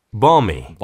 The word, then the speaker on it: balmy: 그러나 읽을 때는 L을 읽지 않습니다. – balmy, balmy라고 읽어주세요.